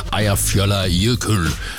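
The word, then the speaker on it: Eyjafjallajökull: Ejer fjoller jøkøl. Mener jeg jo, at den korrekte udtale på et islandsk vulkan, der gik i udbrud herover, skabte en askesky, der nedlagde flytrafik i massevis.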